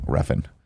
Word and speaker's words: Refn: Nicholas Winding Refn? – Refn, yeah. Yeah. – Okay. – The guy that did Drive? – Mm-hmm. – Yeah, Nicholas Winding Refn.